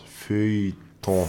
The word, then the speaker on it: Feuilleton: F-E-U-L-L, oder? – Ja. – Das ist dann F-Ö und dann ein stummes J. Feuilleton.